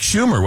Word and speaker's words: Schumer: I believe that was Chuck Schumer, was it not? – Yes. Yes. – Yeah. – The senator's name rhymes with rumor.